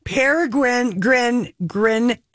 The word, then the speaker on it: Peregrine: Peregrine, as in the falcon.